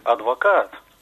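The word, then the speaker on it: Advocaat: Есть такой известный тренер Дик Адвокат. Почему-то везде пишут... в печатных изданиях, просто «адвокат» с одной буквой «а». Хотя... – В голландском должно быть двойное, да? – «Адвокат». Соответственно, и произноситься должно, и писаться должно.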